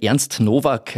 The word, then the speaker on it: Ernst Novak: Fangen wir mit Ernst Nowak.